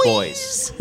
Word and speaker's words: Boise: It's pronounced boys.